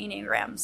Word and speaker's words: Enneagram: The Enneagram, if you're into spiritual stuff, definitely check out the Enneagram. So how would you spell that? – It's E-N-N-E-A-G-R-A-M, Enneagram.